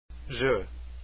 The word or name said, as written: Jeux